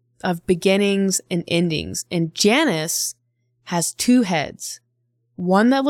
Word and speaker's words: Janus: And then our calendar, what we are on is like the Roman calendar, and January is named after the Roman god Janus, or spelt with a J. – Janus. Janus. – I like Janus better. – Yeah, we're going to go with Janus.